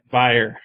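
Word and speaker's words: bier: Beer is how it's pronounced, though. – Well, sleeping on a beer is going to make some people see the wrong thing. – I understand. – So we should pronounce it buyer.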